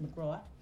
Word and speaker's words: McGrath: September 19th, Kids Johnson and Patrick McGraw, spelled McGrath, but it's pronounced McGraw.